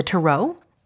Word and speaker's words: tarot: By the time I was maybe 12, I had begun reading the tarot, or the tarot, which is the way we pronounce it.